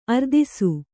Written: ಅರದೇಸಿ

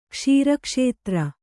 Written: ಕ್ಷೀರಕ್ಷೇತ್ರ

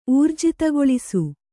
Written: ಊರ್ಜಿತಗೊಳಿಸು

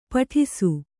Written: ಪಟ್ಟಿಸ

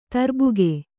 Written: ತರ್ಬುಗ

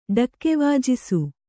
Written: ಢಕ್ಕೆವಾಜಿಸು

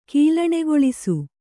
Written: ಕೀಲಣೆಗೊಳಿಸು

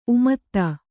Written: ಉಮೇದ

